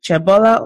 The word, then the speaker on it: Qhobela: Is it Qhobela or Qhobela? – Qhobela. – With a click.